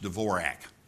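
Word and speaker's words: Dvořák: I mean, there's a composer whose last name is D-V-O-R-A-K. Now, we all know that should be pronounced Dvorak. But it's not. It's Dvorak because he didn't know how to spell his last name. So he forgot the Z-H that should have been in the middle there somewhere.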